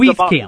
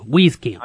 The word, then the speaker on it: Wieskamp: Thinking you're wheezing. You have a cold. You're wheezing. Wieskamp.